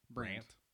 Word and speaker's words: Brandt: Am I saying that right? Sabine Brandit? – Brandt. T-silent.